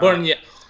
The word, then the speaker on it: Bernier: He's not Bernier.